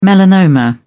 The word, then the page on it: melanoma: British English